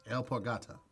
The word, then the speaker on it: alpargata: The word is A-L-P-A-R-G-A-T-A. – Yeah. – I feel like this is... – That's obviously a reptile. – El Porgata.